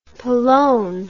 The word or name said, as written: palone